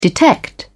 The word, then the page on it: detect: British English